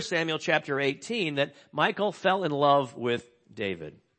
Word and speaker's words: Michal: So not listed here, though, is David's first wife, Michal. M-I-C-H-A-L. It might be pronounced, I guess, in Hebrew, Michal. But anyway, she, will just say Michal.